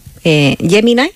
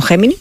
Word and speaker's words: Gemini: Gemini, que he descubierto que se dice así, no Gemini, pero bueno.